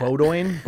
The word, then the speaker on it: Bowdoin: They're playing Bowdoin. – How do you say that? – Bowdoin.